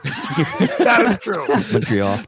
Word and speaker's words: Montreal: Or they'll go Montreal when a local would pronounce M-O-N like M-U-N, Montreal.